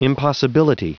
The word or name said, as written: impossibility